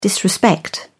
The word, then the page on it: disrespect: British English